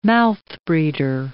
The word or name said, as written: mouthbreeder